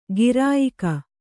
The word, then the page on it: ಗಿರಾಯಿಕ: girāyika